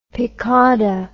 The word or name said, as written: piquada